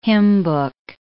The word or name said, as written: hymnbook